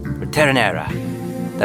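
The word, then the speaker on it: Ritornerà: Ritornera. That's how you say it in Italian.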